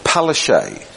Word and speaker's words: Palaszczuk: And the Australians pronounce her name Palaszczuk. – I Australijczycy wymawiają jego imię Palaszczuk. – But it's very different.